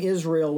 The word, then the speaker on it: Isreal: because my middle name Israel was misspelled on my birth certificate, so my middle name is spelled Israel, so I-S-R-E-A-L,